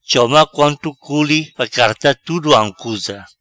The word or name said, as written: Choma Quanto Culi